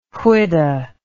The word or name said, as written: whidah